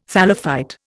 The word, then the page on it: thallophyte: Indian